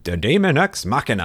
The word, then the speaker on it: Daemon X Machina: The game director came on and he was like, Daemon X Machina. And I was like, I still said it wrong.